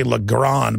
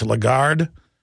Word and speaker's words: Lagarde: I always say Legrand, but it's Lagarde.